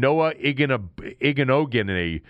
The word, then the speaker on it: Igbinoghene: And then Noah Igbinoghene is the way you would pronounce it, I believe.